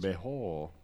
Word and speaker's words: Bejo: Bejo.